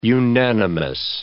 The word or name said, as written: unanimous